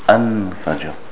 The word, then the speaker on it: fajr: But here we have an-fajr. We pronounce the lam. And here in the ayah, there's a qalqala on the jeen. Faj, an-fajr. And the ra is thick. So the ra, not fajr, fajr. Wal-fajr. There's a qalqala on the jeen, and the ra is mufakham. It's thick here.